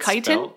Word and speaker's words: chiton: Cryptochitin stellari, there's no way I pronounced that right, that's the scientific name for this thing, is the world's largest chitin. I don't even know if that's right. – Chitin? – Chiton. – Yeah, chitin.